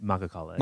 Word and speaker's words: Makhachkala: Which is how you say it? – Makhachkala. – Makhachkala? – Yeah.